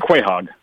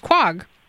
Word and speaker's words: quahog: And just saying sometimes, the sometimes word, pronounced quahog. – Yeah, yeah. quahog. and, and but I always pronounced it as quag.